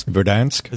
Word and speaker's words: Verdansk: Verdansk. – Is that the proper, I don't know, make sure, checking with my Call of Duty people, Verdansk, where they can loot weapon and gear and fight to be the last team standing.